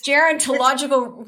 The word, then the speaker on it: gerontological: It's gerontological. Go ahead. – That's why I'm a child psychologist and not a gerontologist. – Right. Gerontological.